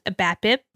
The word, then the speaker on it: BABIP: So this one is pronounced BABIP, and it's BABIP.